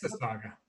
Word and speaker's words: Mississauga: Let's see if I can get this right. Massasauga? Massasauga's own? Is that correct? – Mississauga. – Mississauga. Ah, man, I butchered that one.